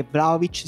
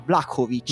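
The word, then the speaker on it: Vlaović: Tra l'altro io ho ascoltato la partita di Serbia-Svezia con la telecronaca serba e posso dirvi che Vlaovic si dice in realtà Vlakovic.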